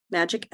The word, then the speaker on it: Majik: Majik LLC spelled like my last name, M A J I K L L C dot com.